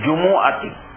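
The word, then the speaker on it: জুমুয়া (جمعة): আরবি ভাষায় এই শব্দের উচ্চারণ হইল জুমুয়া। কোরআনে বলেন, ইজা নুদিয়া লিস-সালাতি মিন ইয়াউমিল জুমুআতি, মিন ইয়াউমিল জুমুআতি বলেন নাই, জুমুআতি বলেছেন। শব্দটার আরবি উচ্চারণ হইল জুমুয়া। উর্দু ফার্সি বাংলা ভাষায় বলা হয় জুমআ।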